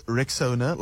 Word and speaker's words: Rexona: So first things first, it's Rexona, like Rex. Owner, so Rexona, so say it right, say it proud,